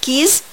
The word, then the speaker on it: keys: keys بنلاحظ هنا ان الاس في اخر كلمة keys بننطقها ز sound keys.